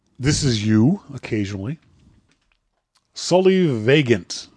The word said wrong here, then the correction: solivagant: I'm saying that wrong. Solivagant? – Solivagant. – S-O-L-I-V-A-G-A-N-T. Vagant. Solivagant.